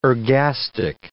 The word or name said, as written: ergastic